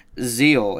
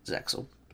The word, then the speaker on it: ZEXAL: It's not pronounced ZEXAL. The X is silent. Oh, I Japan's did not know that. Japan. The X is silent. It's it's Zeal in Japan, but in America it's ZEXAL.